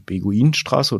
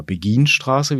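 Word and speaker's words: Beguinenstraße: Das ist ja eine Straße in Helmstedt, äh, die Beguinstraße oder Beginstraße, wie es richtig ausgesprochen wird.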